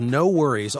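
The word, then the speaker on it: NxWorries: This is a group called No Worries, although it is spelled NX Worries. Somehow that became No Worries.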